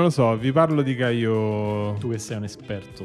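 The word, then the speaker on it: Kaio: E invece parlaci di Gaio e Giorgi. Quindi abbiamo deciso che si dice Caio.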